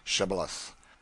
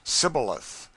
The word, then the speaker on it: Shibboleth: with instructions to let none pass who could not say Shibboleth. The Ephraimites, being of a different tribe, could not frame to pronounce the word right, but called it Sibboleth, which proved them enemies and cost them their lives.